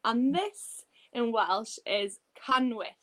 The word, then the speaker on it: canwyll: So we have a double L in the candle in Welsh, and it's pronounced sh. Yeah, and a lot of people can struggle to say that. That's why Welsh is a bit harder, but we say canwyll.